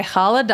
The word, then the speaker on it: challah: I got an everything challah by Challah Dolly. I always say challah wrong, but I'm saying it right.